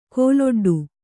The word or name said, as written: ಕಲ್ಲೊಡ್ಡು